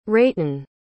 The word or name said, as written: ratin